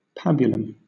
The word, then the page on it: pabulum: British English